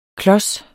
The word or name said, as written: klos